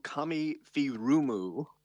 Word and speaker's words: Kami Furumu: It's Kami Furumu. Iga is movie.